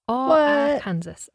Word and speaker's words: Arkansas: Texarkana is a border town straddling the state line between Texas and Arkansas, Arkansas, as it should be pronounced.